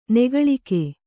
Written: ನಗಲಿಕ

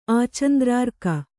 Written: ಆಚಂದ್ರಾರ್ಕ

